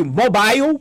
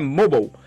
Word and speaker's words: mobile: Segunda grande área, chamada mobile, que às vezes o pessoal chama de mobile, porque lê do inglês, mas não é mobile em inglês, o E normalmente é mudo. Fica a dica aqui do inglês, o E normalmente não se lê em inglês, então é mobile.